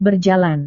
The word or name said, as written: berjalan